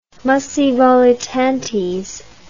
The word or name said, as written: muscae volitantes